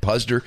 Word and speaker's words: Puzder: I don't even know how to pronounce his last Puzder. – Name off the top of my head. Puzder.